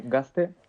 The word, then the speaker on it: Gazte: Sí, Gazte, lo decimos en euskera, se pronuncia con S.